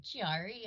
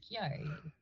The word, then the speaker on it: Chiari: Yeah, it's funny because I'll go to doctor's offices and they'll be like, Chiari. And I was like, it's not Chiari, it's Chiari.